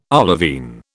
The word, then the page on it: olivine: British English